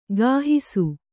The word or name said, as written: ಗಾಹಿಸು